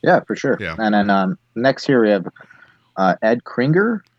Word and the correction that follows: Krieger: Krieger. – That's how it's pronounced. Krieger.